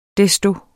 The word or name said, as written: desto